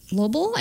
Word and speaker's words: Lobel: Yes, by Arnold Lobel, I believe is how you pronounce the last name.